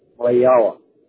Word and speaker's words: Wahiawa: Wahiawa. Is that right? – Wahiawa.